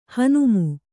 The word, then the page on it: ಹುಣ್ಣಮಿ: Kannada